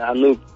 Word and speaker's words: Anouk: Anouk. É Anouk a pronúncia certa dela? – É Anouk. – Anouk. Anouk.